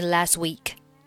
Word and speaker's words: last: Last, 末尾不发音。这个 last 字母 A 和上面 unhappy 字母 A 都是梅花音 A.